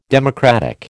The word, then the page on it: democratic: American English